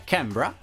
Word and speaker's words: Canberra: Sono stato a Canberra, che insomma la pronuncia, come ben vi piace notare, è Canberra.